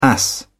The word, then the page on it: ass: British English